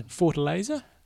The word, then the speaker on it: Fortaleza: Fortaleza.